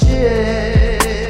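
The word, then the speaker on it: GIF: it's pronounced Jiff. It's pronounced Jiff. I'm the one with the microphone, and I say it's pronounced Jiff.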